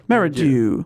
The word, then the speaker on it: Merridew: He, it seems, is the leader of a choir of boys, a certain Jack Merridew. – Dew or Jew? – Dew or D-E-W. – Okay, How would you say that? – Merridew.